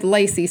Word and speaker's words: Lay-Cee: And it almost makes me want to put in my signature the way I spell my name and then a parenthesis with Lacey so y'all can understand how to spell it because obviously the way that I legally spell my name is the phonetic way of pronouncing my name.